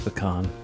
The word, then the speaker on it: pecan: You're allowed to, but there is a correct way to say it. – Yeah, and it's pecan.